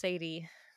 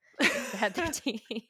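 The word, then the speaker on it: Sad13: And I'm like, well, as the Sadie in the title, I can promise you it's not pronounced Sadie. Sad 13.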